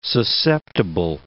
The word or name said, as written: susceptible